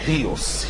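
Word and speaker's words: هيوس (υἱός): مشكلة عادية في كلمة إيوس، هيوس، هي بتنطق دي صوت مزدوج، يا اما بتتنطق اي او وي، انطقها اي لان هي اسهل، تبقى ايوس بتنفس هائي تبقى هيوس.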